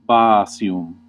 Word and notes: bāsium: Classical; /ˈbaː.si.um/

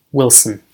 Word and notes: Wilson: American English; [ˈwɪl.sn̩]

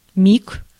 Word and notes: wink: /ˈwɪŋk/

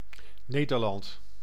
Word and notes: Nederland: NL; [ne.dɛɾ.lɑnt]; /ˈneː.dər.ˌlɑnt/

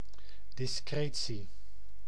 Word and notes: discretie: /dɪsˈkreːsiː/

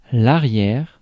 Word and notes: rear: American English; [ˈrɪɚ]; GenAm; /ɹɪɹ/

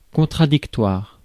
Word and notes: contradictoire: [kɔ̃.tʁa.dik.twaʁ]

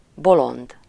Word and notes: bolond: /ˈbolond/

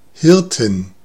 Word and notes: pastore: /pa.ˈsto.re/

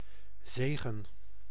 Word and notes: grâce: /ɡʁɑs/

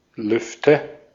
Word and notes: Lüfte: /ˈlʏftə/

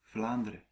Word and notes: Vlaanderen: /ˈvlaːndərə(n)/

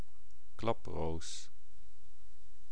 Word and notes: klaproos: /ˈklɑpros/

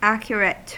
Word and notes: accurate: [ˈæ.kjʊ.ɹət]; /ˈæk.jɚ.ɪt/; American English

